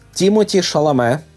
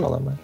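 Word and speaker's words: Timothée Chalamet: Тімоті Шаламе, правильно я вимовляю? – Так, ну, француз. – Тому, мабуть, він Шаламе.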